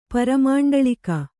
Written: ಪರಮಂಡಳಿಕ

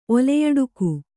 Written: ಓಲೆಯಡಕು

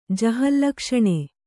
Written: ಜಹಲ್ಲಕ್ಷಣೆ

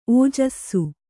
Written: ಔಜಸ

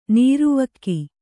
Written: ನೀರ್ವಕ್ಕಿ

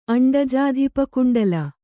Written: ಅಂಡಜಾಧಿಪಕುಂಡಲ

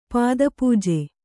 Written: ಪಾದಪೂಜೆ

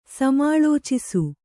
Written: ಸಮಾಲೋಚಿಸು

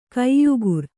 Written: ಕೊಯ್ಗುರಿ

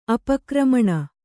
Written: ಅಪಕ್ರಮಣ

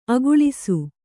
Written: ಅಗಳಿಸು

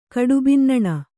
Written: ಕಡುಬಿನ್ನಣ

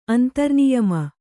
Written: ಅಂತರ್ನಿಯಮ

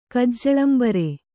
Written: ಕಜ್ಜಳಂಬರೆ

